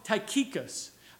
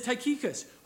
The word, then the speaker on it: Tychicus: But I call him Tychicus.